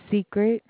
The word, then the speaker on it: Secret: Secret. – Secret? – Yes, ma'am. – Spell it for me, would you please? – S-E-C-R-E-T.